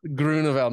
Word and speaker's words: Groeneveld: Now, how did an Aussie get yeah. Groeneveld? Because I'm it's, I do a lot Dutch. of work in South Africa. – That sounds like a pure Dutchy accent, you know.